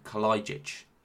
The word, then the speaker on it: Kalajic: I've got the proper pronunciation, Kalajic. – Oh, here we go. Like kaleidoscope.